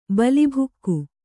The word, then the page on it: ಬಲಿಭುಕ್ಕು: bali bhukku